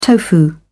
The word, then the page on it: tofu: British English